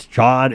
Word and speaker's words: chaud: So you disguised it by dropping the last syllable and just saying she's a chod. – That's so interesting, because that makes sense, right? It's chod. It's short for chada.